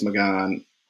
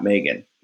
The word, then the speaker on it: Magan: So I was talking to Sean Brogan and Chris Magan, which I found out his name is Magan, not Megan. He's Polish. – Oh, really? – Everybody listening, his name is Magan. So say it right.